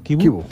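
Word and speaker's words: Chivu: En Tuttosport, Kibu, el técnico del Inter, se pronuncia así, ¿no? – Kibu.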